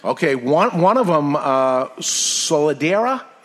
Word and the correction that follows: Solera: Am I saying this right? – Solera. – Solera.